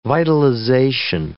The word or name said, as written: vitalization